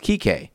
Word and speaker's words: Kike: The ID in question was KIKE_0615, which is a childhood nickname popular in Mexico for people named Enrique, pronounced Kike, like Enrique Kike, right?